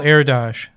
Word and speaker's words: Erdős: Yet Paul Erdős, spelled E-R-D-O-S, but pronounced Erdős, was possibly the most prolific and respected mathematician of the 20th century.